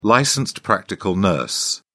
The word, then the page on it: licensed practical nurse: British English